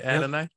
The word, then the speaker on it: Adonai: Did I say that right? Adonai? – Yep.